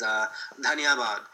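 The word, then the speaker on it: dhanyabad (धन्यवाद): For example, the proper way to say thank you in Hindi is dhanyabad, which means, you know, thank you.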